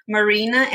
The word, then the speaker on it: Marina: Because Marina and Mariana, it's very similar, right? So they used to, like call me by the wrong one.